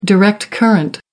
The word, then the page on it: direct current: American English